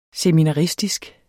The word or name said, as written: seminaristisk